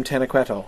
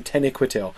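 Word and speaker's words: Taniquetil: It's like the next day when they come in and now Menwe is speaking to them from Tenequetil. Or, sorry, from Tenequetil. I mispronounced that.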